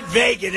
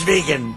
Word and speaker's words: vegan: It's not vegan, it's vegan. I don't know about that stuff. I'm not into it. But he said, it's vegan. Please pronounce that word properly.